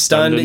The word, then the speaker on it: Stund: S-T-U-N-D. – There you go. – My last name is Stunden. And so I just shortened the E-N off it and made it a word. One syllable rolls off the tongue.